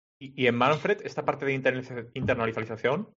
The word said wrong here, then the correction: internacionalización: dilo, dilo, dilo, otra vez, Internacionalización.